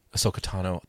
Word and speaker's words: Ahsoka Tano: when Ahsoka Tano, did I say it right? – Ahsoka Tano.